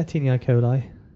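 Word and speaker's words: taenia coli: The first and most obvious of which is the, I'm always going to pronounce this wrong, Tinea coli, is that right? – Yeah, Tinea coli.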